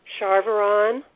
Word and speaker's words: Charveron: And is it Deborah, D-E-B-O-R-A-H or D-E-B-R-A? – D-E-B-R-A. – And what's your last name, please? – Charvaron. – Spell it for me. – C-H-A-R-V-E-R-O-N.